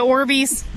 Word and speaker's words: Orbeez: Oh, the Orbeez? – Yeah.